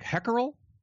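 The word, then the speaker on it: hákarl: But I think it's closer to like Hackerel, mackerel almost. The woman who gave us the tour of the facility where they let the shark rot, essentially, it's the rotting facility, pronounced it that way.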